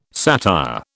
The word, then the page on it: satire: British English